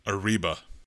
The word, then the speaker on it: Arriba: Arriba. – You got to roll your R's, though, when you say Arriba.